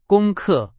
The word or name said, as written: 功课